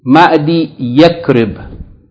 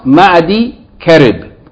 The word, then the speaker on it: معدي كرب: It is not pronounced like this. Even though the correct reading of it, even though it's written with the ya attached to the second word, Yakrib, but it's pronounced Ma'di Karib, not Ma'di Yakrib.